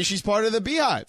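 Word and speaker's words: Beyhive: Oh, by the way, young lady, tweets us, it is definitely Beehive. It's spelled Bay because her name is Beyonce.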